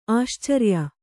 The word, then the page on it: ಆಶ್ಚರ್ಯ: āścarya